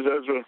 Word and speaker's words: Ezra: My name is Ezra. – How do you spell that? – E-Z-R-A.